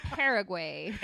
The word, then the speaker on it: Paraguay: Paraguay. – Oh my god. – Paraguay. – Paraguay. – Paraguay. – It sounds like way.